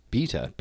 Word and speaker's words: B3ta: It is called Beta. It rhymes with Peter, so it's Beta, B3TA.